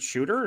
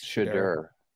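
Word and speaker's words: Shedeur: Is it Shooter or Shadur? – It's Shadur.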